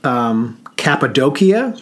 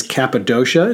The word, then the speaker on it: Cappadocia: Gorome is in a region which they call Cappadocia. We might pronounce it as Cappadocia.